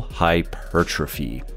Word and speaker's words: hypertrophy: And in this episode of the podcast, we're going to talk about muscle hypertrophy, which can be a rather confusing subject. I mean, it's a confusing word. Many people don't even know how to say it.